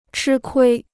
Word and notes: 吃亏: chī kuī

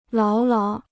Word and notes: 牢牢: láo láo

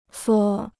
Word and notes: 缩: suō

